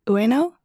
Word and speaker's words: Ueno: Ueno. It's Bueno without the B.